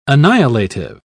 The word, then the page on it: annihilative: British English